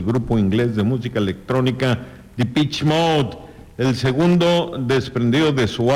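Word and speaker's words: Depeche Mode: Dice, se pronuncia The Pitch Mode, no The Pitch.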